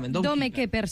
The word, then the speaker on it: Dome Keeper: Recordem que es diu Dome Keeper, ho he dit bé? – Dome Keeper s'escriuria i Dome Keeper es pronunciaria i està exactament disponible al PC, perfecte.